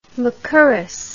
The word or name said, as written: mackerras